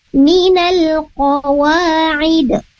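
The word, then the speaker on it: minal qawaid (من القواعد): Nah, harusnya dibaca saat diwakafkan minal qawaid. Nah, tadi Ibu Kiki membacanya dengan tidak mengqalqalkan huruf dal saat diwakafkan di sana. Nah, minal qawaid. Nah, perlu diketahui ketika diwakafkan di sana atau berhenti di sana, maka dibacanya minal qawaid. Adapun kalau dilanjutkan atau diwasalkan, maka tidak perlu ada qalqalah di sana.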